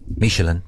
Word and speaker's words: Michelin: Because I said Michelin instead of Michelin, and they said that if I say it again, they would like to eat my liver with some fava beans.